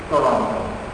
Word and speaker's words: Toronto: Living in 21st century Toronto. 2 weeks ago, somebody told me, if you have to pronounce the second T in Toronto, you are not from here.